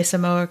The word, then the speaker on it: Samoa: How you say Samoa correctly? – I think you got it. – Did I say it? Samoa? – Yeah.